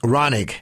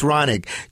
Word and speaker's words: Raunig: Raunig. That was the fabulous Tess Raunig joining us.